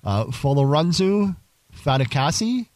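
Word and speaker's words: Folorunso Fatukasi: Folorunsu Fatukasi.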